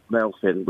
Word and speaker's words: Melfyn: And what it is, my name is spelled M-E-L-F-Y-N, Melfin, which is Welsh. Somebody at the NHS thought it was spelled Melvin, and they put M-E-L-V-I-N.